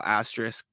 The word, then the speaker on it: asterisk: And the asterisks, I can never pronounce that word, being that if he does play enough. – Oh, Jesus. – Yeah, see? Yeah, exactly. You're going to mock me. – Asterisk. That's what it is.